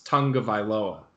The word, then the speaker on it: Tagovailoa: This one's interesting because it's Tua Tagovailoa. I forgot how to say it, but I just remembered. It's Tagovailoa.